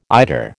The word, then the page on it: eider: American English